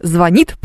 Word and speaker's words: звонит: Евгений, слово «звонит» произносится с ударением на «и».